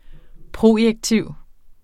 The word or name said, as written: projektiv